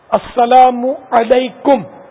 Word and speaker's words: السلام علیکم: السلام علیکم میم پر پیش، اگر میم پر پیش نہیں پڑا السلام علیکم کا تب بھی جواب واجب نہیں ہوتا.